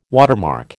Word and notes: watermark: American English

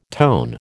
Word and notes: tone: American English

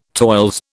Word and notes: toils: British English

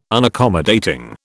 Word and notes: Unaccommodating: British English

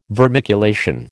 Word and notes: vermiculation: American English

